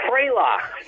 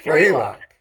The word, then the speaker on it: Frelock: Okay, and another song we have here, and see if I can pronounce this, New Frolock? – Close. Frelock. – Frelock.